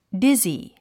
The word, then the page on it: dizzy: dízi; ディズィ